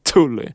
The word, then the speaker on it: Thule: Tule com TH, mas não se pronuncia igual em inglês, que é, é com Tule, o T é bem forte, assim, Tule.